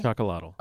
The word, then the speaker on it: Chocolatl: Is it just Chocolatl? – Chocolatl. – I always try to put a little French thing on it, chocolat.